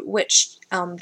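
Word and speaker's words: wych elm: A witch elm. It's spelled W-Y-C-H. – Maybe not with so much emphasis on a T, like a witch. Like yeah. Or a witch. You know? Where's my phone? W-Y-C-H? – Yeah.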